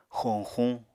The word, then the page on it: xoñxuñ: Apatani